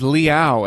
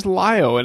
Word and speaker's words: Liao: I mean, when it comes to Battletech, when I first heard somebody say, no, the correct pronunciation is Liao. And I'm like, are you kidding me? It sounds so much better as Lio.